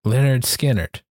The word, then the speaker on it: Lynyrd Skynyrd: Pronounced Lynyrd Skynyrd, or Lynyrd Skynyrd, I guess.